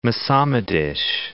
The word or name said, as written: Mocamedes